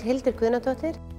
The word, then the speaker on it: Hildur Guðnadóttir: My name is Hildur Guðnadóttir.